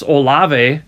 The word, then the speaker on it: Olave: He's he's such a talented receiver, but um, and I'm going to say his name wrong, Chris Olave.